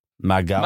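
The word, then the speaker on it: Mag Aodha: How would you pronounce it? M-A-G space A-O-D-H-A. – A-O-D-H-A. Mag Alda? – Mag Alda. – Mag Alda. – Mag Alda. McGee.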